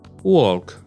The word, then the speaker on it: walk: Walk. In the morning, I walk to work. Yes, it's walk without the l.